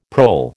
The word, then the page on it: prole: American English